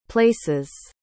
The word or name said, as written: places